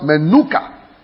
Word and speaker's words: Menucha: That word is spelled Menucha. M-E-N-U-C-H-A-C-H. Or C-H-A-H.